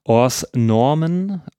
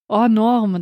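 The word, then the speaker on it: Hors Normes: Im Französ äh, Französischen heißt der Ors Normen. oder ich kann es nicht wirklich. Möchtest du das aussprechen? – Also das S wird schon mal nicht ausgesprochen. Or Norme, dachte ich ja, eigentlich.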